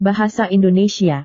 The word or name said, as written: bahasa indonesia